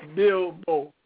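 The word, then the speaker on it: Bilbo: Now, who is Senator, I'm trying to pronounce his name, is it Bailu? Or Congressman Bailu from Mississippi? – Oh, yeah, Congressman Bill Bow from Mississippi.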